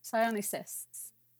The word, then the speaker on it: Psionicists: We are watching a game complete with um, I'm not going to attempt pronounce that word. Scientists. scientists, so, Scientists. Psionicists. Yes, uh, demogorgons and mind flayers.